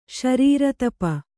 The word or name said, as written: ಶರೀರತಪ